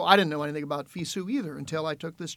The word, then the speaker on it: FISU: FISU is FISU. How they say it.